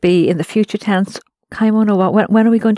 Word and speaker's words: kaimono: Kamono wa. – Kaimono wa. – Oh, it's the two syllables.